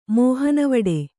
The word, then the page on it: ಮೋಹನವಡೆ: Kannada